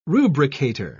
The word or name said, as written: rubricator